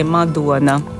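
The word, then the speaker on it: Madona: Sim, porque na Letónia nós lemos tudo de forma como está escrito, com poucas exceções, como O, lê-se Ua. Então, no caso, é Madonna.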